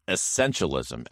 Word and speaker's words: essentialism: Yeah, I said it wrong the first time, by the way. It's essentialism, as in E S S E N T I A L ism.